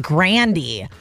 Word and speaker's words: Grande: Because Ariana Grande just revealed her last name was supposed to be pronounced Grandy.